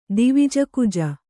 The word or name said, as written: ದಿವಿಜಕುಜ